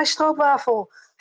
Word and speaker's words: stroopwafel: I want my stroopwafel. Of course, because it's a Dutch cookie, so I'll pronounce it in the proper Dutch way, and you can make it any waffle you like.